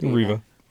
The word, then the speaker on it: Reva: Reva.